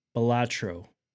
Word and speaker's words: Balatro: Do you know how to say it? – Balatro. – Balatro. I think that's right. – Balatro. – That sounds right.